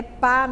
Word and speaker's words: pá: Titia não está falando errado, não, eu não engoli o Z. É pá mesmo, pá de trabalho.